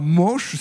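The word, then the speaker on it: Moosch: Alors Manche, cela s'écrit M2OSCH. Si vous nous suivez depuis le début de la semaine, vous l'aviez peut-être déjà noté. M2OSCH, et on dit plutôt Manche.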